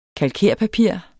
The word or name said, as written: kalkerpapir